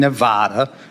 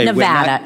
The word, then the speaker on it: Nevada: If a veteran lives at a delivery point in Nevada, okay, Nevada. It's Nevada, sir. Please say it correctly. It's Nevada.